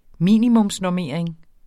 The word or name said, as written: minimumsnormering